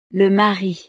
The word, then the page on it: le mari: frances